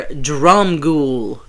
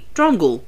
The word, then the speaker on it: Dromgoole: Peter Dromgoole. Dromgoole. Dromgoole. – Okay. – Unless it's Dromgoole, but there was no... Kind of like Svengoole. There was no mention of any pronunciation there.